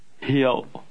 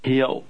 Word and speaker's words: Hiệu: Hiệu. Hiệu. – What other Vietnamese word sounds something like this one? The word understand. They sound similar because the middle of each word is pronounced with a different tone.